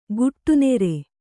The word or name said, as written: ಗುಟ್ಟುನೆರೆ